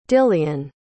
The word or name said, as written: dillian